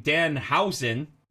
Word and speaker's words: Danhausen: Charlie's referencing Danhausen. Am I pronouncing it right? Hausen? – Yep.